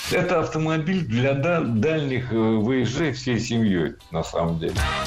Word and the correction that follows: вояжей: Выезжей – неверно. Ударение на окончание переносить не следовало. Правильный вариант – вояжей. Ударение всегда сохраняем на «я» во втором слоге. Вояжи, вояжей, вояжами.